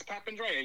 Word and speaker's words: Papandrea: It's Papandrea. You said it right the first time.